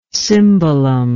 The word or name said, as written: cymbalom